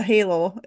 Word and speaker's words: halo: And so when you're doing the halo, it's halo, helio or halo? How do you pronounce it? – Hello. It's Halo.